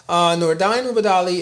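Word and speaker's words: Nordine Oubaali: And now, so let's move on to Nonito Donaire and Nordain Ubadali, right? That's how you say it? – Good one. You got it.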